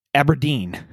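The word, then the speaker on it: abrdn: This year, they have announced that they are changing their name from Standard Life Aberdeen to Aberdn, which is pronounced Aberdeen. – A-B-R.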